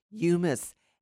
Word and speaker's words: humus: And the dirty word of the day is humus. It's pronounced as if it has a Y in its spelling, humus, H-U-M-U-S.